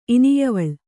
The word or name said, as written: ಇನಿಯವಳ್